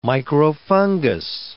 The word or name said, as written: microfungus